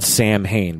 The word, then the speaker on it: Samhain: It's spelled Samhain, but it's pronounced, I think, Samhain.